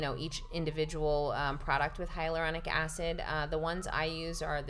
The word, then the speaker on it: hyaluronic acid: And you mentioned using, you know, vaginal moisturizers with how do you pronounce it? – Hyaluronic acid.